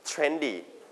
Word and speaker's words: trendy: So you stress on the first or second syllable. Trendy or trendy. First or second? – First we call trendy.